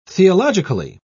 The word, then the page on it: theologically: British English